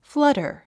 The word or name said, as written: flutter